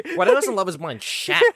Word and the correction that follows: Shake: Shake, bro. Shake. Get his name right.